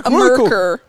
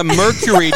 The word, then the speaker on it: Mercury: The Mercur. – Oh, that's a Merker, as the Americans say. – A Merker. – A Merker. – This is like her New Zealand car. It's a Mercury, darling.